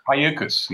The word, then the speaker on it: Cayucos: How do you say that? Cayucos? – Cayucos, yeah.